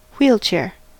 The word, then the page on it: wheelchair: American English